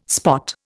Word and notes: spot: Indian

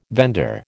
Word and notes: vendor: American English

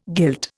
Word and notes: guilt: Indian

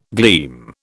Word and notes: gleam: British English

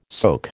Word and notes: soak: American English

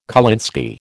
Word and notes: kolinsky: American English

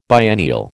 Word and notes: biennial: American English

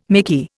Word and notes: mickey: Indian

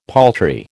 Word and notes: paltry: American English

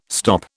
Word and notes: stop: British English

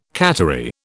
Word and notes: cattery: British English

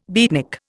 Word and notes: beatnik: Indian

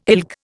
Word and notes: ilk: Indian

